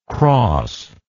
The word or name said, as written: crosser